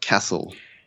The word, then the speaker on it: Kassel: Nope, it's a city called Kassel. – Like with a K? – K-A-S-S-E-L.